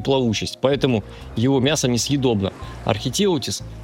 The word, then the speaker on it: архитеутис: В 19 веке наука официально признала, кракен существует, только зовут его гигантский кальмар или архитеутис, там ударение на «е», по-моему, архитеутис, вот, а также его еще более жуткий собрат, колоссальный кальмар.